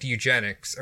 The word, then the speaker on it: eugenics: because Hitler liked eugenics, or, am I saying that right? Eugenics? – Yeah.